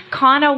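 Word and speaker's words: Conna: I heard Kona Walker, is that how you say her name? – Kana Walker.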